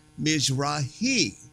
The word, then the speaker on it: Mizrahi: People are saying, you're not saying that right. It's Mizrahi with the but actually, he was on the air with me quite a few years ago, and he made sure that I understood and my listener understood that his name is really pronounced Mizrahi.